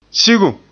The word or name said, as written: sigum